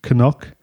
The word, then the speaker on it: Cnoc: The Irish word for hill is Cnoc, that's C-N-O-C, pronounced as you heard there, Cnoc, which you'll often see in place names with the words K-N-O-C-K or knock.